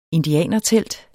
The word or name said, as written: indianertelt